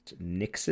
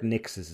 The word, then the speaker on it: Nixxes: The other studio they acquired was, I believe it's pronounced Nixes. – It is, yes. – N-I-X-X-E-S. – From what I've heard online, yes. – Yes. So they've acquired Nixes as well, which is a bit more of an interesting one, because Nixes is kind of a PC port studio.